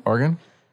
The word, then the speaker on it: Oregon: Oregon? – Oregon.